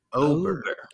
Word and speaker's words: Ober: Well, you see, Juice, Uber is spelled with a U, and this is spelled with an O. – It is so Uber. – Ober Gatlinburg.